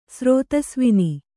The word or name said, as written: ಸ್ರೋತಸ್ವಿನಿ